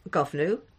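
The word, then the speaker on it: Goibniu: I'm just using Ella Young's pronunciation yeah. – there. It is Govnu, Lochta, Kretna.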